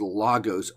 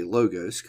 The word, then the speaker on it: logos: Now, that word word in Greek is logos or logos. Logos or logos. I'm just going to say logos because people are familiar with that pronunciation.